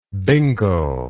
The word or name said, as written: bingle